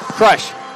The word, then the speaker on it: Crush: Help me with the pronunciation. TJ? – Crush. – Crush. Okay, like man crush.